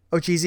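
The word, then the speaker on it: otjize: She's now the word I'm kind of dancing around saying is because I'm going to mispronounce it, is Ochise. Did you guys get a pronunciation? Ochise is how it is. – Ochise. – Okay. – this is the way that they say it in the book.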